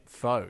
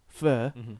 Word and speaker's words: pho: And even though I have this like inherent thing where even though I know it's pronounced fur, I will always say pho.